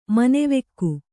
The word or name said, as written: ಮನವುಕ್ಕು